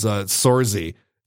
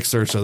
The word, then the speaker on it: Saoirse: I used to think Saoirse Ronan was Sorzy. That's how that was pronounced. Yeah. Sorzy Ronan. I like Saoirse.